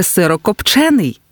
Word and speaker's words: сирокопчений: Сирокопчений чи сирокопчений? Як правильно? Наголос на «е». Сирокопчений. Ось так правильно.